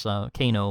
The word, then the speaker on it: Kano: So have a look in the show notes to check out this Kano model. Am I saying it right, Jeffrey? – Yeah, K-A-N-O, that's right.